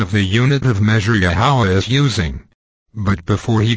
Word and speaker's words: Yahweh: Lord was rendered from the Masoretic substitution of Adonai for YHWH, pronounced, Yahweh.